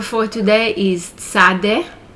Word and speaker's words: Tzadde: Many people refer to it as Tzadik. So Tzadde or Tzadik is the 18th letter of the Hebrew alphabet. It's pronounced like TZ, as in Ritz or Blitz in English.